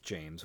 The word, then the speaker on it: Jame: He pronounces his first name like James without the S, Jame. He insists on it.